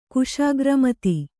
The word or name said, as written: ಕುಶಾಗ್ರಮತಿ